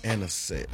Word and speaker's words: Anisette: And I don't know how to pronounce this last one. I think it's National Anisette Day. Anisette. That's A-N-I-S-E-T-T-E. What does that mean? Anisette? – I think you're saying it right.